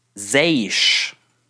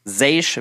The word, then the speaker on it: Zeish: Wir haben jetzt auch recherchiert, wie er richtig ausgesprochen wird. Es ist Zeish. Zeish wird da ausgesprochen.